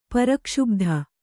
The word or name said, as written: ಪ್ರಕ್ಷುಭಿತ